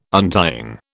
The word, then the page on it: untying: British English